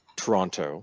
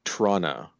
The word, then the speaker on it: Toronto: From lovely, beautiful Toronto, which apparently actually only has two syllables, not three. – Well, that depends on who you speak to, but yes, very commonly it's pronounced Toronto. It's also, unfortunately, sometimes called Trana, which is even worse.